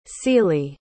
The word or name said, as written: cili